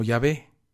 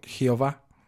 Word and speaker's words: Yahvé: el conocidísimo tetragramatón YHWH, que acabaría pronunciándose como Yahvé. Aunque una mala traducción alemana diera como resultado hace siglos la pronunciación Jehová.